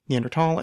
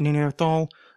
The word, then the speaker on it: Neanderthal: Neanderthal and Neanderthal are both acceptable and widely used in English. The German pronunciation, however, has always been with a T.